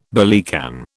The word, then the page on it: billycan: British English